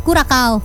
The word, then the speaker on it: Curaçao: Momen haru juga menyelimuti ternyata ya ribuan warga Curaçao bacanya ya. Jadi C pertama itu jadi K, yang kedua jadi C. – Curaçao. Aduh. – Curaçao Mania.